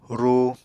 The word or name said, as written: ru